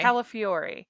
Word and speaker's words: Calafiore: Cody Cagliari. How do you say his last name? – Calafiore. – Yes, who is the big winner of Big Brother All-Stars.